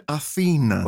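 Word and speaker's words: Athena: ε, ο τρόπο που είναι γραμμένο στα λατινικά διαβάζεται Αθήνα. Α, ναι. ναι. Οπότε, ο ίδιο μα είπε βέβαια που βρέθηκε και στην Αθήνα και στην προβολή τη στέγη ε, ότι εμπνεύστηκε το όνομα από τη Θεά Αθηνά, αλλά στην πραγματικότητα ο λατινικός τίτλος προφέρεται Αθήνα.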